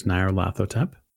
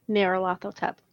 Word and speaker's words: Nyarlathotep: It's going to be is it Narhotep? – Very close. Narlehotep. Well, it's a matter of um, it's really a matter of how you want to pronounce it, but it gets pronounced a lot of different ways. So the way I pronounce it is Nyarlathotep.